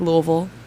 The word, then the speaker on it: Louisville: I say it like Louisville, so there you go. – A native, so I guess this is the only correct way to pronounce it. – Yes, right.